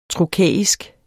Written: trokæisk

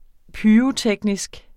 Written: pyroteknisk

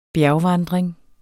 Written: bjergvandring

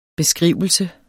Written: beskrivelse

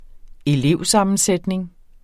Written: elevsammensætning

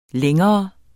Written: lang

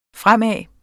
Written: fremad

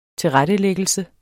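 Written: tilrettelæggelse

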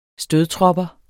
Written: stødtropper

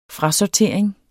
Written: FRASORTERING

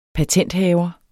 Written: PATENTHAVER

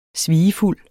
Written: svigefuld

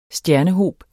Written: stjernehob

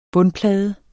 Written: bundplade

